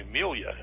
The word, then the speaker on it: Meila: I think they told me Melia is the way they pronounce that. M-E-I-L-A, Melia Gronemeyer.